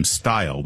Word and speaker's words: Steil: It's too bad that the congressman from Wisconsin pronounces his name Steil. We could call it the Steel Act, and it would actually be really appropriate.